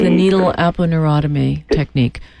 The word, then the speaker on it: aponeurotomy: Aponeurotomy. A-P-O-N-E-U. And N-E-U. – Yeah, Google will find the rest of it for you. It'll R-O-T-O-M-Y. Yeah. Neurotomy. It's spelled like it sounds.